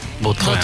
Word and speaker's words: Vautrin: La original francesa, Vautrin. – Vautrin.